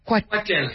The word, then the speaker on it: Katel: How do you say the name of the track? – Katel. Katel is a tribute to my dad.